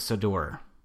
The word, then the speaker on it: Sodor: Whose adventures on TV takes place on the fictional island of Sodor? Sodor. – Can you spell it? – S-O-D-O-R.